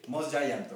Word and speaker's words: Moss Giant: Se, escribe M-O-S-S espacio G-I-A-N-T. Moss Giant, ok.